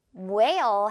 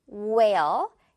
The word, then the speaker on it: whale: whale 해주면 돼요. whale. H는 아예 없어요.